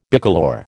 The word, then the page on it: bicolour: American English